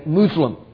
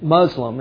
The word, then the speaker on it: Muslim: Most Muslims would say Muslim. But, you know, we pronounce it Muslim in our society, and that's how I pronounce it.